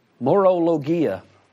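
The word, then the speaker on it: Morologia (μωρολογία): Foolish talk, the Greek word there is, I'm going to spell it for you, M-O-R-O-L-O-G-I-A. Morologia. What we already said what logos meant, right? It is the expression of thought. What's the M-O-R-O? Yeah. Moron talk. Moronic logic. Foolish talk. Wow, that's cool. Morologia.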